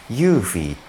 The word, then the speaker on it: Eufy: ユーフィーっていうですね EUFY と書いて UFI っていうロボット掃除機を買いました。